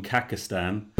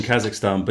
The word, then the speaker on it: Kazakhstan: Against Kazakhstan. Oh, fantastic. – That's a Wales in the mid-90s, isn't it? – Or as my Morgan called them, Kakistan. I think he was mispronouncing Kazakhstan, but he might have just come up with an absolutely brilliant new name for Scotland.